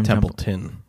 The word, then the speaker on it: Templeton: So, Jim Templeman. No, that's not how you say it. – Jim Templeton.